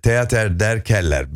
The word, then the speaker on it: Theater der Keller: Köln'de Theater der Keller, bilmiyorum doğru telaffuz, evet, çok güzel.